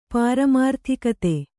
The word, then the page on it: ಪಾರಮಾರ್ಥಿಕತೆ: pāramārthikate